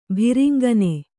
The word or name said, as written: ಭಿರಿಂಗನೆ